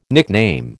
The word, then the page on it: nickname: American English